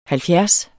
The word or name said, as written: halvfjerds